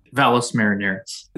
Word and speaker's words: Valles Marineris: Whereabout on Mars is the Valle de Marineris? Am I pronouncing that halfway correctly? – Valles Marineris.